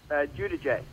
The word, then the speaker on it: Giudice: Did you pronounce it the Italian way, Giudice? – Giudice.